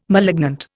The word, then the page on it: malignant: Indian